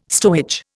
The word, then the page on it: stowage: Indian